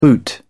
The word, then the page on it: the boot: British English